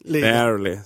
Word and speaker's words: barely: Barely, så uttalar man det.